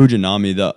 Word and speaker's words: Fujinami: And then the other reliever that I think gives us a nice little infusion of energy out of the bullpen is it Fujiyama, or how do you say his last name? – Fujinami.